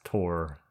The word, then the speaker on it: Thor: It's like a TH, so you pronounce Tor.